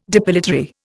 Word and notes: depilatory: Indian

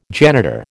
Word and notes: janitor: American English

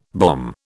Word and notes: bomb: British English

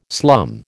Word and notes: slum: American English

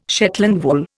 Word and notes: Shetland wool: Indian

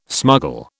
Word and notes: smuggle: British English

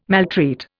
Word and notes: maltreat: Indian

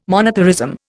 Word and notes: monetarism: Indian

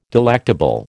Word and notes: delectable: American English

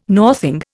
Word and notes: northing: Indian

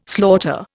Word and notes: slaughter: Indian

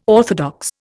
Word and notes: orthodox: Indian

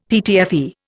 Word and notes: PTFE: Indian

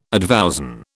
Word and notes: advowson: British English

